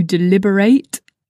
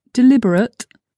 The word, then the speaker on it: deliberate: Pay attention to the pronunciation here. To deliberate is spelt the same as the adjective deliberate, which means intentional. But the two words, I assure you, it might be a bit difficult to hear, but they have different pronunciation. To deliberate is the verb, deliberate is the adjective. So be careful when you pronounce those two words.